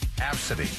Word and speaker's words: Abcde: Her first name is A-B-C-D-E. And you pronounce it Absidy.